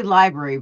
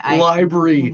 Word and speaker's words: LBRY: How do you pronounce that? – I'd say library, but I would just LBRY. – Library,